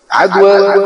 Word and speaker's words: عدوى: عدوى بالألف المقصورة، يعني بعض الناس بينطقها عدوى مش بالهي ولا بالتي، لا عدوى ولا طيرة ولا هامة ولا صفر.